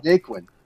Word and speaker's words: Naquin: Tyler Seguin? – Naquin? I don't actually know how to pronounce that. He's been around for a while. – Naquin.